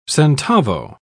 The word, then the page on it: centavo: British English